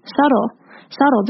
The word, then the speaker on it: subtle: R 跟 actions 也会连在一起，变成 directions。然后这就是连音的部分。再来我要讲的是 subtle 这个字，subtle 它的 b 是不发音的，它是一个 silent b，所以在念的时候就会有点像是在念 s u t t l e 一样，subtle，而不是 subtle，要注意哦，是 subtle。不是 subtle，是 subtle。